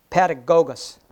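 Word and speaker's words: paidagogos: To share a little history on the meaning of this schoolmaster here, is that the word in Greek actually is pronounced pedagogos, pedagogos, pedagogos.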